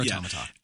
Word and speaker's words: Automata: And most of the time, I want to say it like automata. Like if I look at it and try and read it, like it's just automata. Anyway, I've played maybe 5 or 6 hours of it.